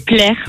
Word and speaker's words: Plaire: Mon nom de famille, c'est Plaire. Comme le verbe, ça s'écrit tout pareil, P-L-E-R-E,